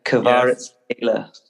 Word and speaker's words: Kvaratskhelia: It's Kvaratskhelia.